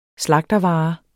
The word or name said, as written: slagtervare